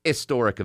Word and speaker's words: historic: Apparently, the old pronunciation of historic up until the 19th century was historic. The H was silent.